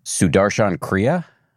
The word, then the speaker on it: Sudarshan Kriya: I'm going to mangle the pronunciation. Sudarshan Kriya, mm-hmm. I believe. – Very good. – Yeah. Was that? Okay, good.